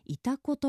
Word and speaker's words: 潮来: 漢字で潮干狩りの潮に来ると書いていたこと読みます